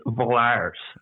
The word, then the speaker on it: Valers: Which I think if you pronounce it correctly, it's Valers. With like a bit of an error. – Valers. – Like a pirate.